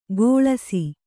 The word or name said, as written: ಕೊಳಸಿ